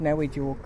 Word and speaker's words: Nawijuwuk: But in our language, it's pronounced Nawijuwuk.